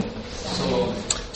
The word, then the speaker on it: Salome: Verses 1 through 8, and when Sabbath was over, Mary Magdalene and Mary, the mother of James and Salome, how do you say that? Salome? Or Salome? – Salome. – Salome. Okay, good.